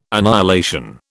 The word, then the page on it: annihilation: British English